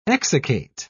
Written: exsiccate

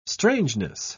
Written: strangeness

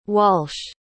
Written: walsh

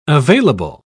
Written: available

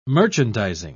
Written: merchandising

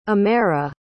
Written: amaira

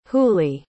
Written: hooley

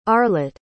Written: arlott

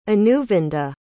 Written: anuvinda

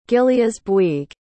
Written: gilleasbuig

